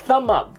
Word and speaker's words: thumb: 呃，下面一个 thumb up，这里提醒注意啊，b 不发音，所以应该读成 Thumb up.